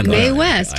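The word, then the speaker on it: Mae West: It's spelled M-A-E, like May. Well, May West, Maybe it was May West.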